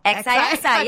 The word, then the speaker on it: XIXI: XIXI. Yeah, you said it right.